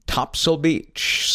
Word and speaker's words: Topsail: It's one of the barrier islands, but if you're a local from Sneed's Ferry, North Carolina, you pronounce it Topsail Beach. So it's a very, very short S-A-I-L. It's almost like you're dropping the A from that particular name.